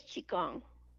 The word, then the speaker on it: Qigong: Is it Qigong? – It is Qigong.